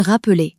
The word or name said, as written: rappeler